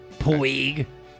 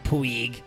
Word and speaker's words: Puig: It's like Stewie Griffin. – What do you call it? – Cool Quip. Puig. His name is Puig.